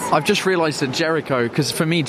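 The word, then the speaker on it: Géricault: Yeah, so in French it's spelled G-E-R-I-C-A-U-L-T, Jericho, whereas for us it's Jericho, J-E-R-I-C-H-O, as Paul said. – C-H-O, that's it.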